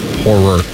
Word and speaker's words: horror: Horror. – Two syllables. Horror. Thank you.